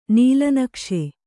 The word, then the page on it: ನೀಲನಕ್ಷೆ: nīla nakṣe